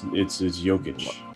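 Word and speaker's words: Jokić: Doncic. Did I say his name right? Doncic. – Jokic? – Doncic. – You think about Luka Doncic. – So it's not Doncic? – No, it's Jokic.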